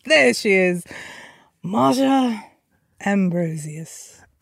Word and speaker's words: Ambrosius: One more time for me, Ambrosius. Is that how you said it? – Ambrosius. – Okay, so some people do say Ambrosius, right? – Okay. – Then it's Ambrosius.